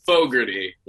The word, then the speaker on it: Fogarty: Fogarty, like a Creedence Clearwater Revival singer, John Fogarty, but he spells it slightly differently with an E instead of an A.